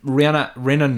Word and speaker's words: Rinna: Like, Rinna knows. I can't say her name. – Yeah. You just want to say Rihanna.